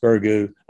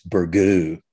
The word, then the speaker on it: Burgoo: By the way, I also appreciate your pronunciation of Burgoo. It is pronounced Burgoo, unless you're some kind of hoity-toity highfalutin person. It's Burgoo, which is more French, I guess.